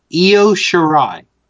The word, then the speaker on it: Io Shirai: Io, how do you say that? Shira? – Io. Io Shirai.